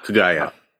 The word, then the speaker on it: Kaguya: Kaguya.